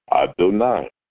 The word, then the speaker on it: Adonai: Nun, Yud, it would be a dot under the Nun as an E, but it's pronounced I, A-I. Adonai. We understand that?